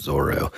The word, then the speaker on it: Zoro: And I mean, the characters, like I thought Zoro, and in the manga, it's spelled Z-O-L-O. Hmm. I've read that that's because that was Oda's way of like bypassing the copyright. – Yeah. – And so he wrote it, the English translation is Zolo, but it's pronounced Zoro in the anime and everything.